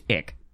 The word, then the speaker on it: ich: Called ick. – Literally, is that what it's called? – Yes. I-C-H, pronounced ick.